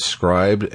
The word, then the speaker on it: Scribd: Scribd. I hate how people do that. It's pronounced this way, but we spell it that way.